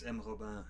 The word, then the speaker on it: Robin: Is it Monsieur Robin or is it M. Robin? – It's M. Robin. – M. Robin.